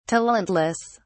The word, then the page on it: talentless: British English